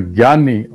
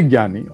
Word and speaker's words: Jnani: If you are a Jnani or a J-N-A-N-I, different pronunciations are being used for this, Jnani, Jnani.